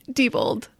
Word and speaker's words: Diebold: Okay, Kayla, how do I say your last name? – Diebold.